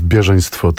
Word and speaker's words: Bieżeństwo: Tak, spektakl, który jest wynikiem naszej współpracy, zatytułowany jest Bieżeństwo 1915 i to akcentowanie na pierwszą sylabę nie jest przypadkowe, ponieważ jest to słowo z pochodzenia rosyjskiego, ale funkcjonujące również w innych językach,